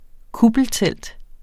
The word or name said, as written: kuppeltelt